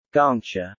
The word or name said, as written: Gongcha